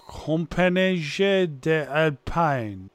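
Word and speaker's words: Compagnie des Alpes: Okay, so yeah, this is going into a park in Belgium called Compagnie des Alpines. – Ah, that's 100% correct. That is how you say it.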